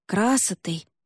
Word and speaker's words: красотой: Прощание девушки с девичьей волей, красотой, именно с ударением на первый слог, сопровождалось пением.